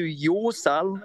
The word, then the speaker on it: Jorsal: It goes kind of deep, but essentially the journey, right, is called Returning to Yorsal, or Jorsal, it looks like. So it's spelled J-O-R-S-A-L.